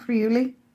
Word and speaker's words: Friuli: Friuli? – Yep, Friuli.